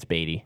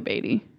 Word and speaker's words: Beatty: I think it's Beatty. – Probably Beatty, because that's how Warren Beatty's name is spelled.